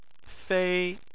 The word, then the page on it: fei: 1